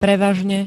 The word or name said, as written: prevažne